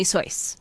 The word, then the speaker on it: Seuss: That the correct pronunciation is actually Seuss, because he was German.